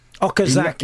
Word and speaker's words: Okazaki: He's a trier, and he's Okazaki.